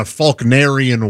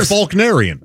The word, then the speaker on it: Faulknerian: You have to look at it in a Faulknerian way. It's a narrator. – You're spelling Faulknerian properly. There's no L. – I don't pronounce. You don't pronounce. You say Faulkner. – Right. – Yeah, said Faulknerian.